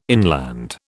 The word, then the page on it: inland: British English